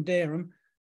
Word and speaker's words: Dereham: You say, I'm from Dereham. They might spell it D-E-R-U-M because that's what they hear. Actually, it's spelled D-E-R-E-H-A-M. That's how you spell it.